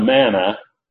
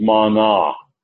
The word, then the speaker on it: mana: It's not manna, it's mana. And I did that on purpose.